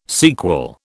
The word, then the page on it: sequel: British English